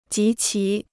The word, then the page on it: 及其: jí qí